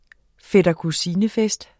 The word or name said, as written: fætter